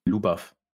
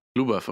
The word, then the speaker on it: Ljubav: Wie spricht man das aus? – Lubav. – Lubav, okay. Weil es wird ja irgendwie L-J-U-B-A-V geschrieben.